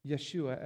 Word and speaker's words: Yeshua: So, of course, his given name is Jesus, or Yeshua, as the Hebrew people would pronounce it.